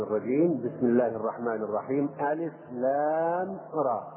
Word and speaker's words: الر: هذه حروف تسمى بالحروف المقطعة وتنطق مقطعة، يعني تنطق ألف ألف ولام لام وراء را. ما هي كلمة؟ ما هي كلمة؟ لا، هي حروف تجمع في هذا الشكل وتنطق حروفا: ألف لام ميم، ألف لام راء، ألف لام ميم راء، حاء ميم، طاء هاء، ياء سين.